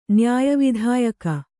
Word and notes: ನ್ಯಾಯವಿಧಾಯಕ: Kannada

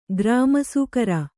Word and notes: ಗ್ರಾಮಸೂಕರ: Kannada

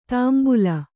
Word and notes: ತಾಂಬುಲ: tāmbula

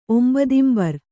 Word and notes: ಒಂಬದಿಂಬರ್: kannada

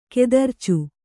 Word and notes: ಕತರ್ಚೆ: kannada

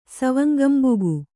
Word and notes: ಸವಂಗಂಬುಗು: kannada

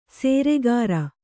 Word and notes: ಸೇರೆಗಾರ: sēreg`ra

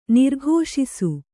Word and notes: ನಿರೀಕ್ಷಿಸು: nirīkṣisu

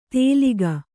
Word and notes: ತೆಲಿಗ: kannada

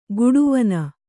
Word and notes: ಗುಡುವನ: guḍuvana

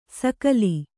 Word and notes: ಸೂಗಲಿ: Kannada

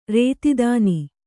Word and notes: ಋತುದಾನ: řtudāna

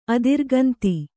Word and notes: ಅದಿರುಗಂತಿ: Kannada

